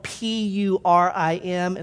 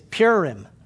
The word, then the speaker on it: Purim: It's spelled P U R I M. And let me tell you something the way I want to say it is Purim, but it's not pronounced like that, but it's Purim.